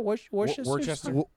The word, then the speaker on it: Worcestershire: Worcestershire sauce.